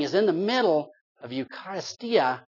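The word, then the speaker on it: eucharistia (εὐχαριστία): And in Greek, it's pronounced, if I can do this, eucharistia. Eucharistia.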